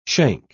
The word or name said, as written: shank